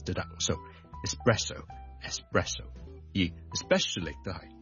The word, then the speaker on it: espresso: 今天有个朋友讲一讲这个话题，就是呢有两个字 ES 字开头的，分别是 ESPECIALLY especially 即特别地呢，尤其是啦 especially 第二个字呢都是 ES 开头的，就是 ESPRESSO espresso espresso espresso 即是意式的特浓咖啡。我们这两个字的 ES 呢，我们都不要将它读成 X，不要 expresso，不要 especially， but espresso e 或者 e 都得，直接加个 S，中间唔好加咗个 K 音，因为 K 音呢我哋 EX 我哋先会有 K 音嘅。